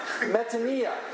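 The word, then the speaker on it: metanoia (μετάνοια): Or better, our word in Greek, metanoia. The O and the I are pronounced E, for those of you who want to learn Greek.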